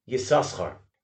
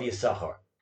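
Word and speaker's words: Yissachar: It is for that reason that we do not pronounce the second sin in Yisachar, because that sin was given away to his son Yoiv. Reb Chaim Kanievsky, in his Sefer Taima Dekra, says that the Chazon Ish would tell the Baal Koreh, specifically in Parshas Vayetze, to pronounce Yisachar's name Yisoschar, and in the rest of the Torah to pronounce it Yisachar.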